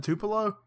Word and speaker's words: Tupelo: And I hope I didn't pronounce that wrong. Tupelo? – Isn't it Tupelo? – Tupelo. There you go.